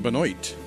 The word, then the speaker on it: Benoit: Tab Benoit, or as they say in Benoit, Mississippi, Tab Benoit, rhymes with Detroit and adroit.